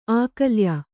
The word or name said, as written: ಅಕಲಾಯ